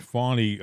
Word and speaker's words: Fani: We know that Fani, you have to say it right, Fani went up and talked to Kamala.